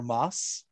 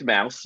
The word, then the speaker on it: Maus: It's pronounced Mouse, right? Or Moss. I've been calling Mouse, yeah. – I always assume Mouse. – M-A-U-S, yeah.